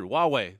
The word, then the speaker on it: Huawei: Huawei, that's right. H-U-A-W-E-I. It doesn't look like it should be pronounced Huawei, but this is like the Chinese Apple company.